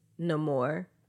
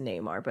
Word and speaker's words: Namor: He says it, no more. but like it's traditionally pronounced Neymar, but I think that they changed it because they did have more dialect so that it's Mayan.